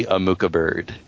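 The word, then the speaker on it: Amuka-Bird: It stars Channing Tatum, Mila Kunis, and Nikki Amuka Bird. I love that name. – None of those names are pronounced right. – Nikki Amuka Bird. – That one is.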